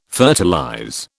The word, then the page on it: fertilize: British English